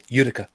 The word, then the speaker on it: Utica: Utica or Utica? No, you got it right the first time. Utica.